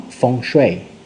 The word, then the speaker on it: Feng Shui: que se pronuncia en chino Feng Shui,